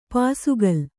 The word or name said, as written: ಪುಸುಕಲು